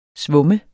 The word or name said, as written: svumme